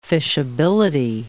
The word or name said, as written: fishability